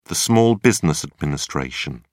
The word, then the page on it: Small Business Administration: British English